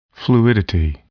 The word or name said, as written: fluidity